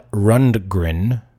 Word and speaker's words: Rundgren: Todd Rundgren.